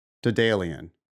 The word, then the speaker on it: Daedalian: So the Wikipedia has a Southern England pronunciation. Daedalian.